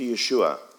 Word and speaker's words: Yeshua: When we're baptized, we get baptized into Jesus, into Yeshua. That's how you say it in Hebrew.